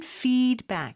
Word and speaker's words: feedback: 그런데 영어로는 feedback. 이렇게 F 발음을 해주셔야 돼요.